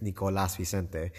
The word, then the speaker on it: Nicolas Vicente: Like, obviously, my technically name is Nicolas Vicente. – Oh, okay. – But nobody ever pronounces it that way, and I don't really care.